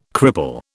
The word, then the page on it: cripple: British English